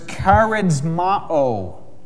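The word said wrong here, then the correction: charizomai (χαρίζομαι): I actually pronounced that wrong. It's charizomai, the Greek word to give.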